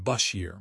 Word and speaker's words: Beshear: They are looking toward alternatives like Andy Beshear, pronounced Bashir, Mark Kelly, or Wes Moore to provide that fresh start.